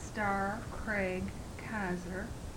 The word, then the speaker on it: Star Craig Kaiser: My name is Star Craig Kaiser, StarCraigKaiser.